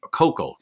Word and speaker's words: Koukl: Koukl, pardon me. Everybody needs to know, pay attention, pronounce his name correctly.